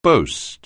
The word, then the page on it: boast: anglais